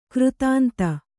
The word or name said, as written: ಕೃತಾಂತ